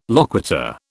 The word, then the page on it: loquitur: British English